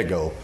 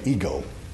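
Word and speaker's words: ego (ἐγώ): The Greeks say ego like Lego. We say ego, okay?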